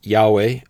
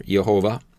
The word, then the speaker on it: Yahweh: It is the four Hebrew letters Yod, He, Vav, He, sometimes pronounced Yahweh or Yehovah.